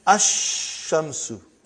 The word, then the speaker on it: ash-shamsu: And then, Ash-Shamsu. No al. No lamb. When I say, I shouldn't say al. I should say no lamb.